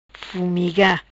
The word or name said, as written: Fumiga